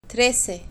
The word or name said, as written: trece